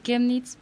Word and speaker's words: Chemnitz: Yo soy de Alemania, soy trabajadora social y trabajo en el Centro Sociocultural AJZ Chemnitz, pronunciándolo manera alemán.